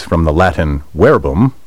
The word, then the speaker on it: verbum: That's because most English words containing V-E-R-B come from the Latin verbum, word. By the way, verbum is spelled V-E-R-B-U-M, but in classical Latin, V is pronounced like W.